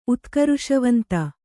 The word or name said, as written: ಉತ್ಕರುಷವಂತ